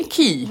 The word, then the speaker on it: Quay: How do you say Quay? Key. – We say key. We say the Quay House.